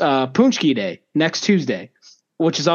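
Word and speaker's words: pączki: And in Polish circles, the the treat is a punchki. It's it's spelled P A Z P A Z.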